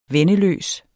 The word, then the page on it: venneløs: [ ˈvεnə- ]